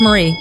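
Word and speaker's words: Maree: Or Mary, or M-A-R-E-E. I read it. – Yeah, Marie. That's what it is. It's pronounced Marie.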